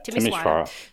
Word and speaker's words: Timișoara: You can fly in to Timișoara. Am I pronouncing that right? – Yeah, Timișoara.